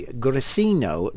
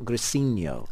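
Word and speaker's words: Grissino: Claudia says, yes, please, pronounce the restaurant name as it should be. Grisino, not Grisino. – Grisino. – It's an Italian word, not a Spanish one.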